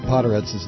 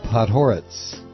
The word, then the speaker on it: Podhoretz: I mispronounce Podhoretz's name. It's Podhoretz. Podhoretz. Accent on the second syllable.